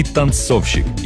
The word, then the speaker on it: танцовщик: Ударение в слове «танцовщик» точно так же падает на второй слог, как и в слове «танцовщица».